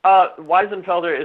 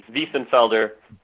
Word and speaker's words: Wiesenfelder: Weisenfelder is what I've grown up with. – Okay. – I think in Germany it would be Wiesenfelder.